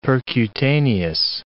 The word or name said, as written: percutaneous